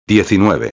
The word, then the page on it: 19: espanhol